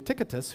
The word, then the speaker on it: Tychicus: Gaius of Derbe was another one of his disciples, and, and the Asians, these were Asian men, Tychicus, and that's actually how you pronounce his name, Tychicus,